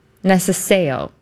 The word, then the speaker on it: necesejo: So the C is pronounced like a TS sound. – Okay. – Necesejo. – Necesejo. Okay. – Mm-hmm. And the J is like a Y sound.